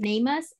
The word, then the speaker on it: NamUs: Through, I think it's pronounced Namus, N-A-M-U-S.